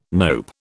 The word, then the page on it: nope: British English